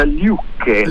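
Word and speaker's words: Lykke: No, è norvegese, si pronuncia Liukke.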